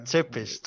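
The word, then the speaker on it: Țepeș: Então é Tepes, né? – Tsepes.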